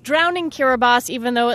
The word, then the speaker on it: Kiribati: There's a country in the Pacific called Kiribati, though it's spelled like Kiribati.